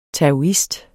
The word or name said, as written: taoist